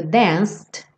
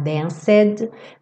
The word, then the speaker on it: danced: A gente pega o dedo danced, que está no passado. Lembra que o E não é pronunciado, você não vai falar danced, danced. E aí, juntando no with, danced with you.